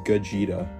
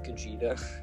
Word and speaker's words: Gogeta: His name, it's pronounced Gogeta. – Gogeta.